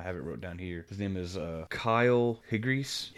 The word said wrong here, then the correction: Higgins: I think it is pronounced that way. Well, let me make sure that's how you pronounce it, so I'm not a huge D-bag. Kyle Higgins. Written by Kyle Higgins and Ryan Parrott.